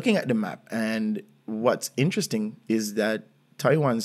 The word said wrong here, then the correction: Taoyuan: City, ta, ta, I'm ho- I hope I'm pronouncing it right, right? Taiwan um, is bigger than Taipei. – Taoyuan. – Taoyuan. Taoyuan.